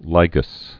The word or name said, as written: lygus bug